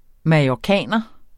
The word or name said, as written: mallorciner